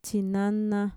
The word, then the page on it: txinana: Mixtec